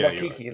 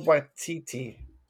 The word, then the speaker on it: Waititi: Am I saying Waikiki, like the island? – You're saying Waititi like the torch. – Taika Waititi.